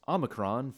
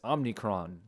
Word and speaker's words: Omicron: I noticed while editing the episode that I pronounced Omicron for the new COVID variant as Omnicron, so please excuse my inability to read and enjoy the rest of the episode.